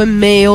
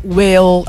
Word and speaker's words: Mhaol: Bold Men, questa band che è una band irlandese, quindi si scrive M-H-A-O-L, ma si pronuncia un po' come Meo o Weo, dipende da dove vivi nell'Irlanda.